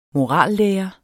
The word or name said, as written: morallære